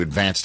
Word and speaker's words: Advance: It's spelled like the word, you know, you advance to go, collect $200. They call it the Advance in Staten Island. I don't know why they put the accent on the first syllable. They just do.